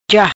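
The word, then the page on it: Ja: patua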